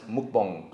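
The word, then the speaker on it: mukbong (먹방): It's pronounced mukbong. – Oh, mukbong. – So, for the bong heads out there, you smoke, you hit the muk, and you hit the bong.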